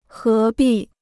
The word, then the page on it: 何必: hé bì